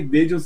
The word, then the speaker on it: Daejeon: Eu, cumprimentando aqui, só um detalhe, é, vale frisar que o Dejan, a nossa, nosso arroba é o arroba Dejan Dejan se escreve Daegeon. Então, D-A-E-J-E-O-N FCBR, porque fica difícil, porque, né, Complicado.